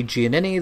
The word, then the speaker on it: Giannini: Giannini. That last name is spelled G-I-A-N-N-I-N-I, and you can find my work, whether it be writing or editing, at Talk Film Society.